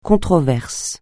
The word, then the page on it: controverse: francais